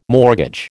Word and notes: mortgage: American English

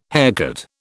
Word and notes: haircut: British English

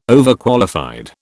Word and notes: Overqualified: British English

